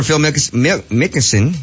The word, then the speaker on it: Phil Mickelson: Golfer Phil Mickelson.